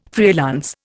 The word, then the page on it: freelance: Indian